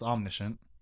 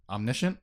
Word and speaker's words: omniscient: He's almost omniscient. Omniscient? – Yeah. – Why did I say omniscient? I said it. Put the wrong emphasis on the wrong syllable over there.